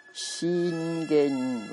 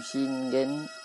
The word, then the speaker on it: Shingen: Entonces, como digo, se pronuncia Shingen, ¿vale? Sería la pronunciación Shingen, Shingen, aquí en español, ¿no?